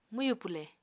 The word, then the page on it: mwyù pwle: Apatani